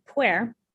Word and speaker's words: Pu'er: And now pu'er, which is.